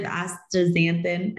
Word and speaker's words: astaxanthin: So I I have heard of astaxanthin. I don't know if I said that right. – Yeah, you nailed, nailed it.